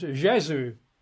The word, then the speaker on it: Gesù: In Italian, it's Gesù, G-E-S-U, Gesù.